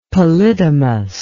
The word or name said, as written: polydomous